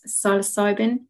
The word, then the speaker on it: psilocybin: You know, there is the microdosing to help with microdosing psilocybin.